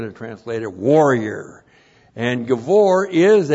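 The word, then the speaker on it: gibbor: And then we have the adjective givor. And We've seen this many times. If you've been a faithful attendant here, G-I-B-B, long O-R, Givor. And Givor literally means strong or mighty.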